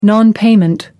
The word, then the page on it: nonpayment: British English